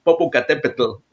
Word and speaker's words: Popocatépetl: Yeah, it's Popo, but it's Popocatépetl. I know it's hard, but yeah, that's the full name of the volcano.